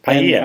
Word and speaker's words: Paia: How do you say that? – Paia? – Okay. – Paia. I mean, Paia.